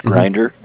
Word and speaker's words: Grinder: It's just my first name at RMS, Randy Mary Sam, Roller, R-O-L-L-E-R, Grinder, G-R-I-N-D-E-R, .com.